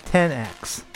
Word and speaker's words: iPhone XS: You're supposed to pronounce it 10X.